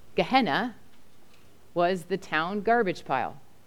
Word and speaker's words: Gehenna: We can pronounce this Greek word, Gehenna, and it means Valley of Hinnom.